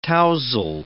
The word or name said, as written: tousle